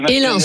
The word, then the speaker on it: Hélin: Hélin ou Héloun, je ne sais pas comment ça se prononce. Bonjour. – C'est Hélin, Hélin, super. Bah, et c'est très rare comme prénom.